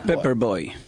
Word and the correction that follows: Paper: Paper. – Bueno, en algunos lugares se pronuncia. – En la escuela de... – Paper Boy.